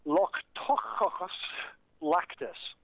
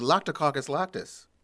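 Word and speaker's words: Lactococcus lactis: If approved, it would be the Loctococcus lactis. I don't think I said that right, but at this point, I don't care. – I believe that was the Loctococcus lactis.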